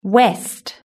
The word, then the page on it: west: british-english